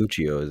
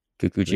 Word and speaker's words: Kikuchiyo: Like Kiki Chuyo. Butchered the name, I'm sure. But Toshiro Mifune's character. – It's Kikuchiyo, isn't it? – Kikuchiyo.